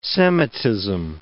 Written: Semitism

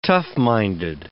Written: tough-minded

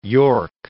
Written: york

